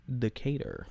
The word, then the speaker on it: Decatur: Stephen Decatur.